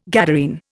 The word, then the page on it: Gadarene: Indian